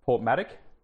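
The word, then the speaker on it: Porthmadog: I got a soft spot for narrow gauge, and I stayed in, Port Maddock, even though it's written as like Port Madog.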